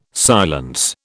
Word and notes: silence: British English

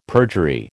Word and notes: perjury: American English

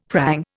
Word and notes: prang: Indian

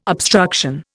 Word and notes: obstruction: Indian

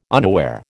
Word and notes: unaware: American English

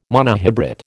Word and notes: monohybrid: American English

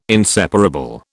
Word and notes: inseparable: British English

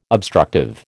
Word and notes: obstructive: American English

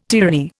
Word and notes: tyranny: Indian

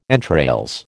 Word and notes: entrails: American English

